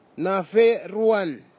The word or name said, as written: nafeiruan